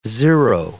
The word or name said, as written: zero